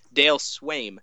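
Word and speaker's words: Sveum: Dale Swaim um, is their hitting coach and is a former manager.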